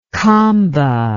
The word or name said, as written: kamba